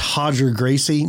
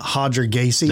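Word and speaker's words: Roger Gracie: Like if the R is at the very beginning of the word, it's only pronounced H then. – That's why it's Hodger Gracie, not Hodger Gacy.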